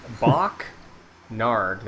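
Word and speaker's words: Bokk Naarg: Bok Narg, that is spelled B-O-K-K space N-A-A-R-G. – N-A-A-R-G.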